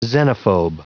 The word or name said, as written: xenophobe